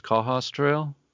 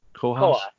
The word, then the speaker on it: Cohos Trail: And we're here to talk about it's pronounced Cohos Trail. – Cohos.